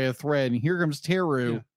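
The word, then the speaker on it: Therru: How do you pronounce her name? – Teru? – Yeah, Teru.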